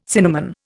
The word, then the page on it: cinnamon: Indian